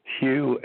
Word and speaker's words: Hugh: My name is Hugh, H-U-G-H. The last name, Charlson, is spelled T like Tom, R-A-U-L-S like Sam, E like Edward, N like Nancy.